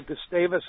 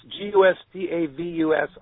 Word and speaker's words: Gustavus: But just do some checking on Gustavus, Gustavus, Alaska. It's kind of pronounced kind of strange, but beautiful spot, and basically one road and lots of bicycles and a few cars, and just a fun place to do.